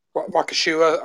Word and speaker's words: Waukesha: Is that how you pronounce it, in Wisconsin, Waukesha? – Waukesha or something like that. – Waukesha, that's it, yeah.